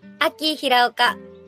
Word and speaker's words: Aki Hiraoka: Aki Hiraoka.